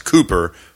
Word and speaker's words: Cowper: One of those is of William, I heard recently it's pronounced Cooper, but it looks like Cowper, C-O-W-P-E-R.